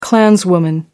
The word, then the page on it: clanswoman: American English